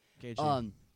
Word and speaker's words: Gaethje: Gaethje, that's how you pronounce it. Gaethje.